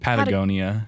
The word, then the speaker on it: Patagonia: And then there's Patagonia. Did I say that right? – Patagonia. – Patagonia. There we go.